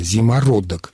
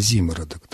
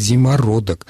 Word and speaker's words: зимородок: Там, естественно, рассказывается и о зимородке, кстати, правильное, согласно словарю, ударение все-таки такое, о котором говорила Елена Николаевна, зимородок. И в словаре под восклицательным знаком говорится неправильно зимородок. Так что словарь нам рекомендует говорить зимородок.